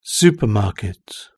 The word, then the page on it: supermarket: British English